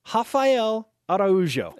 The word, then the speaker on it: Rafael Araujo: At center, Rafael Araujo.